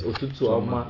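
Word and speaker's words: Ụtụtụ ọma: just say Otutu Oma, and that's not how to pronounce it. Yeah, it's Otutu Oma.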